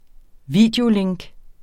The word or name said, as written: videolink